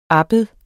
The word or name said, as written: abbed